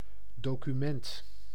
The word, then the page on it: document: American English; /ˈdɑkjʊmənt/